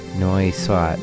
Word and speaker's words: Nowy Świat: The track was called Nowy, Świat. That's how you say it in Polish.